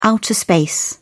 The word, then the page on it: outer space: British English